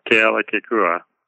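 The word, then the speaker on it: Kealakekua: Oh, Ke'ala'kekua.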